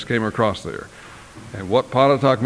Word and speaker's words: Pontotoc: And it's a small town, Pontotoc, Texas is its name. Some people have asked me to spell it. I will do that. I don't have a blackboard. P-O-N-T-O-T-O-C.